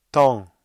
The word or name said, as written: temps